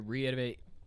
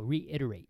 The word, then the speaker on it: reiterate: And just to reiterate, I say that right? – No, reiterate. – Reiterate. Okay, that's why he's my guy.